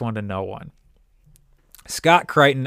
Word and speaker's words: Crichton: And they draft a guy by the name of, I believe it is pronounced Scott Crichton. C-R-I-C-H-T-O-N. It's either Crichton or Crichton, but I'm pretty sure it's Crichton. This is a thing.